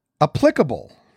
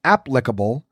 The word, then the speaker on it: applicable: T-R-A-N hyphen S-H-E-N-T. So transient is how it's supposed to be said. 0 for 2. Applicable. The first syllable is the one that should be emphasized, as in applicable, rather than the second, which is applicable.